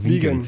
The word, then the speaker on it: vegan: In Klammern Englisch ausgesprochen, also vegan. vegan.